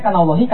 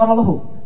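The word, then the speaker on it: kana Allahu (كان الله): Bacanya kana Allahi, kana Allah atau kana Allahu? Pasti kana Allahu. Ada orang baca kana Allah, salah bacanya dari jarak 100 km. Paham itu saya. Karena dalam bahasa Arab kana itu pasti u belakangnya, kana Allahu.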